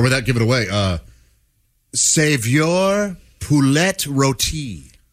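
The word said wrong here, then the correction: saveur: It's a savour, but go ahead.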